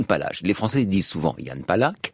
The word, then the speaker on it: Jan Palach: Il semble que la vraie prononciation, euh, chimiquement pure, pour le coup, ce soit Yann Palach, avec ce re qui ressemble à la, rota, espagnole, ou au ch allemand.